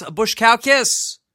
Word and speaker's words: Baskauskas: He is the winner of Survivor Exile Island, and I'm going to say his name correctly. It's Aras Bushkalkis.